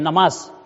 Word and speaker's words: نماز: نون کے اوپر زبر ہوتی ہے، نماز کیا بولنا ہے بولیے، نماز پڑھنا ہے، تو نماز کا لفظ تلفظ بھی صحیح ہونا چاہیے ہمیں۔